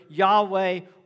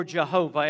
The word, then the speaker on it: Yahweh: The term Lord there, the name Lord there, is the Hebrew name for God, as best we can tell, pronounced either Yahweh or Jehovah. Every time in the Old Testament you see capital L-O-R-D, know that it is the name, best we understand, Yahweh for God.